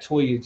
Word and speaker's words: Toya: You can find me at The Political Plug, Toya at Toya G. It's spelled how you pronounce it, actually. So TOYUHGE. Yeah, right.